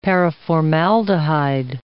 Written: paraformaldehyde

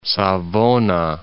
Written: Savona

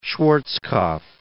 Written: Schwarzkopf

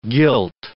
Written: guilt

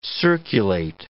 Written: circulate

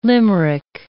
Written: limerick

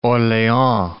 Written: Orleans

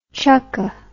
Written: chucke

